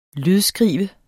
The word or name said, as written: lydskrive